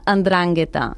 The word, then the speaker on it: 'Ndrangheta: I per què se li diu 'Ndrangheta? Es diu, ho dic bé, per cert? Com ho diries? – Sí, es pronuncia 'Ndrangheta.